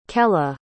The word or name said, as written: kella